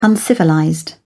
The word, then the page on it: uncivilized: British English